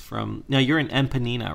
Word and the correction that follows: Ipanema: No, Ipanema. But that's funny. – Oh, how did I say it? – You said it like empanada almost.